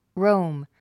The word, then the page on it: roam: róum; ロォム